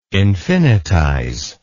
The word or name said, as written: infinitize